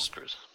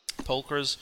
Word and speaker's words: sepulchres: is that how you pronounce that? Sepulchres? – Sepulchres. – Sepulchres uh, lined the cavern walls, each carved with intricate reliefs and designs and marked with dwarfish runes.